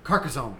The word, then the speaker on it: Carcassonne: Carcassonne? – Carcassonne, thank you.